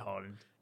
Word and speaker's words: Haaland: Yeah, well, no, it's Erling Haaland.